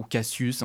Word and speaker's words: Cassius: Je ne sais pas si vous vous souvenez de ce groupe électro qui s'appelait Cassius, qui en fait, alors ou Cassius, hein, si on veut le prononcer, à la française.